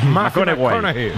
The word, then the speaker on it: McConaughey: Hay que ir corrigiendo la pronunciación. – McConaughey. Matthew McConaughey. Bueno, pues el Mateo.